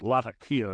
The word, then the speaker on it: Latakia: I've been called down for mispronouncing it several times. But Latakia, but Latakia is what I was told.